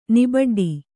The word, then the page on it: ನಿಬಡ್ಡಿ: Kannada